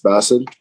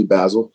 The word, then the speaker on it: Basil: My name is, so in Arabic, it's pronounced Basil, but everyone calls me Basil.